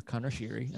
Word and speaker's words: Connor Sheary: Connor Sheary, I was making jokes, obviously. Hi. – Sorry. Yes, go ahead. – I was making jokes, just, you know, how we usually do with Connor Sheary, and that is how you pronounce his name.